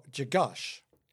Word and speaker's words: Grzegorz: That first name, Valerie, G-R-Z-E-G-O-R-Z. – Okay, double Z's. – Yeah, but I guess it's pronounced Jagosh.